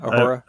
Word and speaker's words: Uhura: I can never say her name. Uhura? Uhura. – Yeah, that's right. – Uhura.